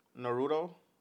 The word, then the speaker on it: Naruto: You know, How do you say Naruto properly? – Naruto? – Okay. Yeah. Just want to make sure.